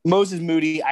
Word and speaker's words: Moses Moody: Moses Moody.